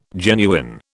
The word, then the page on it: genuine: British English